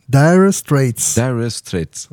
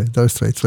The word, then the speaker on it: Dire Straits: Reprononce. – Dire Straits. – Ouais, Dire Straits, ouais.